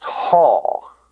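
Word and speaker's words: haw (ὁ): It's pronounced haw. Haw.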